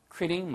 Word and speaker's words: quit: 그래서 I'm thinking of quitting my job. 예, 발음 주의하세요. 절대 quit이 아니고요. quit. quit. 입술이 나갔다가 들어오셔야 돼요. 그래서 quit. quit. quitting.